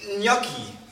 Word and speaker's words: Gnocchi: Es heißt aber Gnocchi.